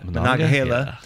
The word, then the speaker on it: Monongahela: Monongahela.